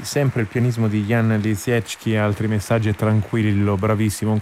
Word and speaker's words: Lisiecki: Bene, trova della Deutsche Grammophon, lui pubblica per Deutsche Grammophon, credo che la pronuncia esatta sia Lisiecki.